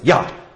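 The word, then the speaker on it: ja: It's spelled J-A-H, but they pronounce it yah, okay? So they have no J sound in German.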